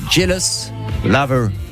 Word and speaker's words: Jealous Lover: Extrait de leur 26e album qui sort donc aujourd'hui, Jealous Lover. Ça va, je l'ai bien prononcé. – C'est très bien.